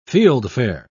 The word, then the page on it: fieldfare: British English